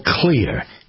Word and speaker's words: Xlear: An introduction for you to a product called Clear, X-L-E-A-R. It's pronounced clear, but it's spelled with an X and not a C.